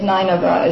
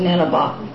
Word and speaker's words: Nineveh: Most of you will recognize E.J.'s Nineveh is Nineveh. It's fine. Same place by either pronunciation,